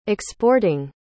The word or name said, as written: exporting